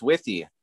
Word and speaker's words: Wythe: It's W-Y-T-H-E, Wythe maybe, the Wythe Raceway. – I think it's Withy. – Withy, okay, we'll go with Withy.